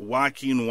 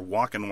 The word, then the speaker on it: Joaquin: And we got Joaquin Wild, or Walkin' Wild, as I found myself constantly saying as I say his name more frequently.